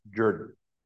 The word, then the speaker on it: Jordan: If you read it in a book, it looks like Jordan, but it's pronounced Jordan because this is Georgia.